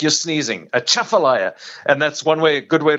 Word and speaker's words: Atchafalaya: Yeah, so I'll tell you the easiest way to remember that is it's like you're sneezing. You go, Atchafalaya. – Atchafalaya.